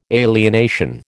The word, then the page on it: alienation: American English